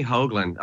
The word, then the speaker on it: Hoagland: Tony, am I saying his name right? Hoagland? – Yes, Tony Hoagland.